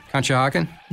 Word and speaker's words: Conshohocken: C-O-N-S-H-O-H-O. – Conshohocken? – Nice.